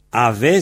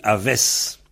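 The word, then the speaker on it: a vez: Alors, ave s'écrit particule A, comme toujours. Ve s'écrit V-E-Z. En léon, on dit aves. Un amzer aves brau de nau.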